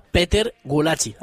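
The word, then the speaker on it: Péter Gulácsi: Bueno, habéis escuchado ya al final cómo Negroles corregía la pronunciación, y es que se pronuncia Peter Gulácsi.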